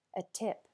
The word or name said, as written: a tip